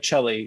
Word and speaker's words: Celli: It rhymes with a lot of things. And so if I had nicknames, they weren't good. There was Celli Belly and Smelly Celli and Vermicelli and all those things.